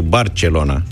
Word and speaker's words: Barcelona: Eu când am fost în 2005, anul 2005, acum 15 ani, pentru prima și singura dată în viața mea, în orașul Barcelona, așa, că așa se pronunță pe catalană, Primul lucru care m-a impresionat acolo au fost șoferii de autobuz.